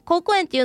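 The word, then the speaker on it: 好古園: 高校園っていうのは姫路城のすぐ近くにある庭園でございます。好きっていう字と古いっていう字と園で高校園って読みます。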